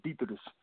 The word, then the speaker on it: Dietrich: Actually, my name is Dietrich. My name is German. But a lot of people can't say my name. The proper way to say it in English is Detrich.